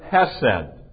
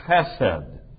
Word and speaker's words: chesed: Now, that word loving kindness is the Hebrew word hesed. Or if I would dare to try to pronounce it properly, chesed, kind of that guttural sound that you find in Hebrew.